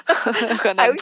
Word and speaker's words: Henan: Henan Province, or Henan Province, I'm not pronouncing it correctly. – Yeah, I will correct those, don't worry. – Thank you. – Henan Province, yeah.